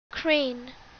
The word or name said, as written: crane